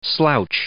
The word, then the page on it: slouch: verb